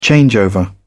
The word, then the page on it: changeover: British English